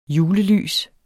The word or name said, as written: julelys